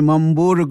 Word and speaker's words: Mammburu: All right, Livhuwani Mammburu, good evening to you, sir, and thank you very much for joining us.